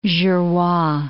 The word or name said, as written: Jurua